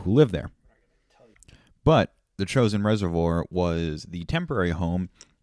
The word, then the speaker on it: Chosin: So one thing comes to another, and there are three American divisions being encircled around a reservoir that was mispronounced by the Japanese from the original Korean name written down on a map, and then the Japanese name, which is a mispronunciation of the Korean name, was mispronounced by the Americans, in which it was referred to as Chosin Reservoir.